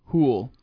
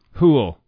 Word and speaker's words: Hoole: Yes, Stephen Poole. – No, Hoole. – Hoole. – Rhymes with pool. – That's right. – Or cool.